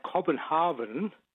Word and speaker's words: Copenhagen: Well, I've always said Copenhagen because my Swedish colleagues always said Copenhagen. Hagen. – Yes. – But given that it's spelled like Copenhagen, I checked before I called, and the Danish way is Copenhagen. – Horben. – Yeah, H-A-W. – So neither Hagen nor Hagen, but no.